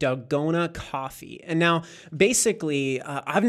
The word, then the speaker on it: dalgona: I don't know how to it's spelled D-A-L-G-O-N-A, dalgona. Mm-hmm. That's how I say it.